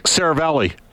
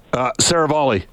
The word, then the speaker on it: Saravalli: It's exactly like it's spelled, Saravalli. Think tennis, serve and volley. – All right. Frank Saravalli. Saravalli joining us.